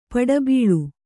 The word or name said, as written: ಪುಟಬಾಳೆ